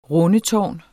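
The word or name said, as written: RUNDETÅRN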